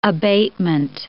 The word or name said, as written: abatement